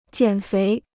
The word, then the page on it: 减肥: jiǎn féi